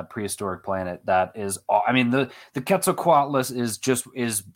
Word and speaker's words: Quetzalcoatlus: And so to hear David Attenborough say it in Prehistoric Planet, I was like, so it's basically like Atlas, and then it's Quetzalcoatlus, and she said it the exact same way.